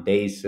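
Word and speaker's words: beys: Bays is both means angry in Yiddish, but it's also the name of the second letter of the Hebrew alphabet in the Yiddish pronunciation, better known as Bet these days.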